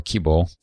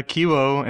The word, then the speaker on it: Kibo: Yeah, so that's Kibo, or Kibo, with a long O at the end. – Awesome. Well, thank you. That was a phenomenal, touching on Kibo